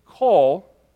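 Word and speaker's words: call (קול): Now, the word call, Q-O-L, as we kind of transliterate it into English, pronounced call, means sound or voice.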